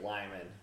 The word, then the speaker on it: Limon: just like Lyman, which could, you know, should be Limon, right? – Should it? – Well, if you pronounce it the way the Spanish do.